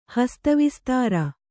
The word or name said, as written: ಹಸ್ತವಿಸ್ತಾರ